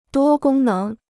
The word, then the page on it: 多功能: duō gōng néng